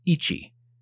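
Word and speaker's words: ichi (一): and the third would look like the kanji for the number one, which in Japanese is pronounced ichi.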